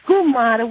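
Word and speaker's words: comare: Dr. Savage, it's Gumara is actually not pronounced Guma like they say on The Sopranos. That's just very poor diction. It's Gumara, which means godmother, which over the years became a code word, slang word, for the other woman or the other men.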